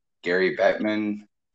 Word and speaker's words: Bettman: Gary Bettman, did I get his name right? – Yeah.